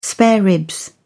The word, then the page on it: spare ribs: British English